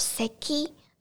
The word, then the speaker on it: 咳: 其实“ 咳” 这个汉字，它在日文的读音叫做 seki seki。然后如果你要说有咳嗽这个症状的话，你可以搭配一个动词叫做 seki ga deru。 seki ga deru 就是有咳嗽这个症状可以搭配的动词，然后第二个叫做肌肉酸痛，kinniku tsu，kinniku tsu，汉字是筋肉痛。